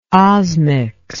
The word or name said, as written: osmics